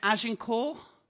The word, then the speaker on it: Agincourt: Is this how you say it? Agincourt? That's the one, okay.